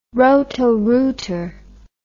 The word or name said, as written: Roto-Rooter